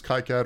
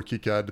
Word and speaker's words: KiCad: I use KiCad or KiCad, depending on what you say, how you say it.